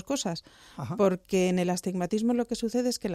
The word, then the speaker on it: astigmatismo: ¿Qué es eso del astigmatismo? – Sí, el astigmatismo, que además es que les cuesta mucho. Claro. Atismatismo, no saben muy bien muchas veces cómo pronunciarlo correctamente.